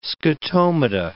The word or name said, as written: scotomata